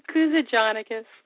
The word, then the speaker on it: Kuzujanakis: Kuzujanakis.